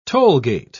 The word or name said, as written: tollgate